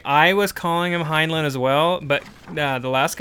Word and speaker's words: Heinlein: No, I looked it up. The Robert Heinlein Society said, like, I did the little audio thing on there. It's Heinlein.